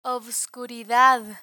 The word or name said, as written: obscuridad